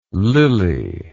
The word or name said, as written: lili